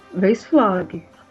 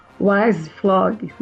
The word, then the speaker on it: Weiszflog: Aqui a gente costuma falar Walter Weisflog, mas a pronúncia não está correta. Não né? está correta? – Não, não está correta, porque... É pelo menos o que a gente já viu, né, já ouviu de, de alemães falando, a pronúncia do, do, do W e do E é, é, diferente disso, né? É mais um Weisflog, né? O E mais como um A, alguma coisa assim, né?